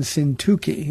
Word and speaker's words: Syntyche: These are two women, Yodia and Syntyche, is how you pronounce the names.